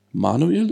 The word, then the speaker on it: Manuel: Und deswegen sprechen wir heute mit dem guten ähm, Manuel, ist das richtig? Habe ich richtig verstanden, ne? – Genau, man kennt mich auch nur unter meinem Künstlernamen Manuel, aber du hast Manuel schon gut ausgesprochen.